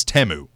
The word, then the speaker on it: Temu: I found out that we've been pronouncing Temu wrong this entire time. I thought it was Temu. – Yeah. – Or Temu this entire time. Apparently it's Temu.